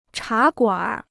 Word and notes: 茶馆儿: chá guǎnr

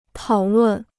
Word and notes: 讨论: tǎo lùn

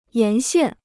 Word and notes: 沿线: yán xiàn

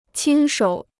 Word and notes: 亲手: qīn shǒu

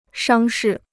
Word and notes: 伤势: shāng shì

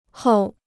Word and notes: 后: hòu

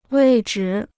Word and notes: 位置: wèi zhi

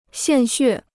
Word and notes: 献血: xiàn xuè

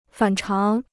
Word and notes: 反常: fǎn cháng

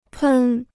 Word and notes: 喷: pèn

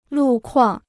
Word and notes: 路况: lù kuàng

